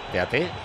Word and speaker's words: Teate: ¿Cómo se pronuncia? Teate. Teate. Teat. – Yo creo que Teat. – Teat, sí, Teat. – Artur Teat. – Francés es Teat.